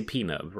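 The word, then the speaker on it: Pnub: I could never really get the actual pronunciation. – P-nub. It's just P-nub. I think they say P-nub, right? They say like a hard P-nub.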